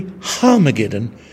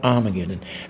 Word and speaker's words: Harmageddon: It has a starts with an H. It starts with an A in Greek, but there's a little comma called a rough breathing, which says, pronounce this with an H. So it's really Harmageddon, not Armageddon.